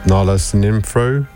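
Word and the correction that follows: Sinephro: No, that was not how you say it, is it? How do you say it, Sam? – Sinefro.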